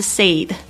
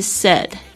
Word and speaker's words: said: He said, 他说，这个 said, 大家特别注意一下他发音哦，不是 said, 是 said. 这个 AI 呢，发这个 e 的短音，这个嘴巴微微张开呢，很轻松的